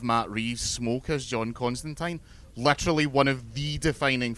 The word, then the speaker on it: Constantine: When in 2013-ish, when they made the Constantine TV show, which is how you pronounce his name, it's how they say it in the comics, he makes that point several times, and I once got a reply from Neil Gaiman on Twitter confirming it.